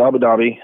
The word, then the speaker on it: Abu Dhabi: I mean, you just got back from, how you can pronounce it, Abu Dhabi? Is that right? – I was in Abu Dhabi.